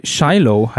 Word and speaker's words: Shiloh: Es geht nämlich um die Schlacht bei Shiloh, heißt es. Also so spricht man es, glaube ich, aus. S-H-I-L-O-H, Shiloh.